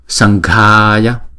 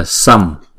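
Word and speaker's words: saṅghāya: Do đó chúng ta phải chuyển cái chữ anuswara này thành chữ ng, đúng không? Thì chúng ta đọc là sanghaya, sanghaya, san chứ không phải là sam, đúng không? Nếu chúng ta đọc sam thì cũng đúng, nhưng mà thực ra tiếng Phạn chúng ta phải đọc là sanghaya, sanghaya, đúng không?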